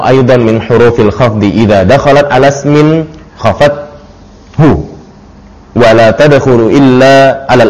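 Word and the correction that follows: khafadathu (خفضته): At-tasi'u allamu aidan min hurufil khafdi idza dakhalat ala ismin khafadathu wala tadkhulu illa ala al-asma'i.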